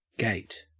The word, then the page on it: gate: Australian English